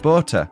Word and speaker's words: Botha: Theans is pronounced Teens and Botha is pronounced Botha. They are both Afrikaans names. We don't normally pronounce the H and we have hard T's.